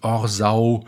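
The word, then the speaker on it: Orsoy: Dasselbe gilt für das gegenüber linksrheinisch gelegene Örtchen Orsau, das tatsächlich so ausgesprochen wird, obwohl man es Orsoi mit Y am Schluss schreibt.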